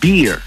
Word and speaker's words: beer: Porque la barba se pronuncia beard, el oso se pronuncia bear, y la cerveza se pronuncia beer. ¿Eh? Pero con R al final.